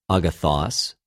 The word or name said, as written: ἀγαθος